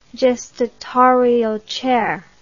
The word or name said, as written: gestatorial chair